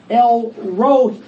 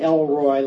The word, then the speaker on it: El Roi: The name, the Hebrew name for this God of seeing is El Roi. Now, I've been mispronouncing it for a long time. I've been saying Elroy like Elroy in the Jetsons.